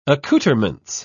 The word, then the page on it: accouterment: British English